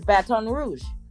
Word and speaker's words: Baton Rouge: Yeah, because people don't pronounce it right. It's Baton Rouge, and so I didn't grow up with my father.